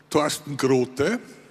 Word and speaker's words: Thorsten Grote: His full name is Thorsten Grote. Excuse me, I'm pronouncing it the German way.